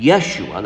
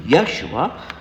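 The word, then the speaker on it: Yeshua (ישוע): And Yeshua, that's the way it's pronounced in Hebrew, Yeshua,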